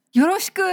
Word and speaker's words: よろしく: よろしく。